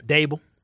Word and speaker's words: Daboll: Dable.